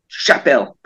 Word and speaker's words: Chapelle: It's a feminine word, but the curious bit is the double L would normally be like in beer, like a ticket would normally be have that E sound in it. But it, actually, they pronounce it like the English L, Chapelle, basically.